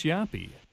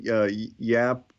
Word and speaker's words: Jaap: Yeah, I think it's funny that, you know, he's the stupendous Yappy, and his first name is really Yap, spelled with a J, of course, rather than a Y. But yeah, I think you're probably saying that right, Jaap Roker.